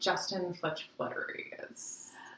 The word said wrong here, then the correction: Fletchley: Justin Fletch Fletchley.